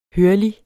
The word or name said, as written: hørlig